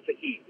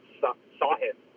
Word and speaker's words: Sahith: It's not Sahith. It's Sahith. Sahith? Sahith. Sahith Theegawa.